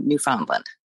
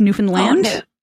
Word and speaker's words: Newfoundland: but I'm just going to say Newfoundland. – Yay! That's not technically the right pronunciation. I'm going to have to correct you on that. It's Newfoundland.